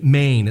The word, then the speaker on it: Men: They worshipped a moon god in Antioch named Mane. It's spelled Men, but it's named after the lunar god they worshipped.